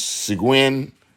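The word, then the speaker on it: Şengün: And Alperin, and I think they say his name's Seguin, but it's spelled S-E-N-G-U-N, Sengun.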